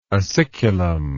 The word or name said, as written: aciculum